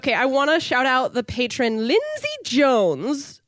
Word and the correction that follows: Jonas: Sorry, I said it wrong. One more time. Lindsay Jonas.